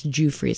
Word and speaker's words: Giuffre: I think it's Giuffre, is how you say that.